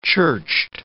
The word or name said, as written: churched